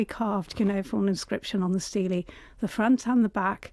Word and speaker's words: stele: the stele, as I gather it's pronounced, the stele